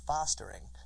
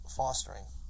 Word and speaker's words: fostering: it's F-O-S-T-E-R, of course, but the short O is an ah sound, so you'd think the word is fostering, and that's what Samantha says, and phonetically that's, you know, entirely correct, but when I hear other people say it, and the way I say it, it's always like the A-W sound, the fostering.